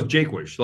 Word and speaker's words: Jaquish: It's pretty close, Jaquish, like Jake and Wish.